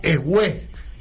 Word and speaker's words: Ewe: la nacionalidad Ewe, E-W-E, con acento en la segunda E, la fraccionaron en cuatro colonias distintas, gobernadas por cuatro potencias distintas, en cuatro idiomas distintos.